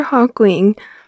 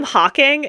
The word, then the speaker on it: Hawkwing: It is Arthur Hawkwing. – Okay, Hawkwing. I always call him Hawking, and that's not correct.